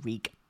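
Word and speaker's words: Rhug: As in R-E-E. – It's R-H-U-G, but you pronounce it Rieg.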